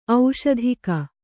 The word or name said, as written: ಔಷಧಿಕ